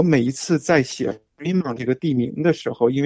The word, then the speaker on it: Fremont: 对，还记得 Fremont 是个法文，所以他既然是法文，最后的 T 本来是不发音的啊。嗯，呃，所以我们很多人就直接把它念成 Fremont，那个 T 不念出来，其实是对的。